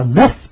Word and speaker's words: neuf: Số 9 thì là tiếng Pháp đọc là neuf, tiếng La Mã đọc là nove, novo, là N-O-V.